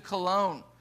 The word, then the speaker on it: Cologne: Tyndall then in 1525 traveled to Cologne, that is how it's pronounced, and he had an amanuensis, a guy who helped him write down these things, named William Roy.